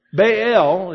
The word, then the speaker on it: Baal: Baal is the proper way to pronounce it.